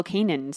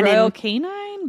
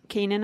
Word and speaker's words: Royal Canin: Royal Canine, but without the E. Mm. – I don't know. Because they have cat food too. So I don't. – So how did you pronounce it again? – Canin, I think. Royal Canin.